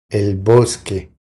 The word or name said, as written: El bosque